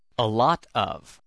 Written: a lot of

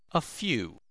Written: a few

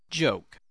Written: joke

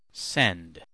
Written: send

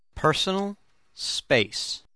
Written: personal space

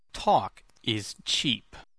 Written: talk is cheap

